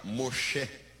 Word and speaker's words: Moshe: Na pronúncia hebraica, Moshe, tirado das águas, extraído das águas.